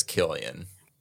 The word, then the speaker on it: Cillian: I thought it was Killian. – That's what I say too. – Killian, I used to call him Cillian when I was a kid, or not a kid, but you know, like whatever.